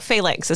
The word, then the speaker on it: phalanx: No, phalanx is how it's pronounced.